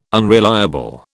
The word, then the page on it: unreliable: British English